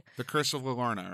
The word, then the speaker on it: Llorona: La Llorona, because it's two L's.